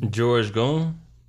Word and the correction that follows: Young: Yo, I think it's pronounced Young.